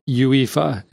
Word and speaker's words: UEFA: So I believe this is typically pronounced UEFA. It's UEFA.